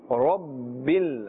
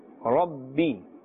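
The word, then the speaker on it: Rabbi (رب): Alhamdu lillahi Rabbil Alameen. Rabbi. Can you see the Ra? The Ra has a Fatha and therefore it's pronounced with a full mouth. We will not say Rabbi. Rabbi is incorrect.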